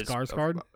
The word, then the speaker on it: Skarsgård: Bill Skarsgård, or however you say his name, is Skarsgård.